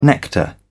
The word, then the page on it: nectar: British English